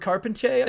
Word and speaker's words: Carpentier: Edouard Carpentier, I think is how it's pronounced.